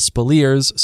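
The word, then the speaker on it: Speleers: It's spelled and pronounced Spilliers.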